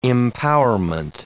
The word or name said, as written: empowerment